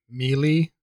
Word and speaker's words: Melee: But what was extra annoying is that the fucking announcer goes, Melee! He like says it very prominently.